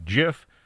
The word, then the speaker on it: GIF: And yes, I said GIF, even though it's spelled G-I-F. There's been a long-going debate over how to pronounce it. The person who created it says it's GIF. So, GIF it is.